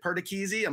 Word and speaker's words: Perchese: Is it Perchese? Am I saying it properly? Are you happy with my Italian pronunciation from one paisan to another? – Absolutely. You nailed it.